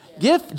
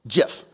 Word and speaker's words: GIF: GIF, GIF, it's kind of a debate, isn't it? And the complicated thing about this one is that depending on who you talk to, it could actually be either. The dictionary allows it to go either way, but the person who came up with the graphic interchange format prefers the pronunciation GIF.